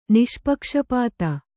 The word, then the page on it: ನಿಷ್ಪಕ್ಷಪಾತಿ: niṣpakṣapāti